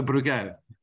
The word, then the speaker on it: Bruegel: It's Bruegel? – We insist on the spelling. – So Bruegel.